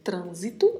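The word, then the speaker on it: tránsito: A letra A é pronunciada bem mais aberta no espanhol e mais fechada no português. Falamos trânsito em português e em espanhol vamos falar trânsito. Trânsito. Atenção porque tem uma letra N logo depois da letra A e ela precisa ser pronunciada. E ainda temos uma letra S que não tem som de Z, como no português.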